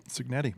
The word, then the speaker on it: Cignetti: Coaches Kurt Cignetti, did I say his name right? – Cignetti, yeah. – Cignetti.